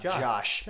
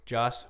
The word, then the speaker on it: Joss: Until no, not Josh. – Joss, I said it right. No? – Joss. – Whedon. Whedon. Okay. All right. If you're going to pronounce my friend's name, pronounce it right.